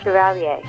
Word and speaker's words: Chevalier: Is it Chevalier? Is that how you pronounce it? – Yes. – All right. – Yeah, Chevalier. Yeah, which is about Joseph Bologna, who is a contemporary of Mozart.